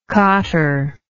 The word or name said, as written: cotter